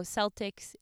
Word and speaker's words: Celtics: Okay, according to Google, it is Celtics. Probably because they think Americans can't figure out how to say it the other way. – That would be my assumption. – So Celtics is correct.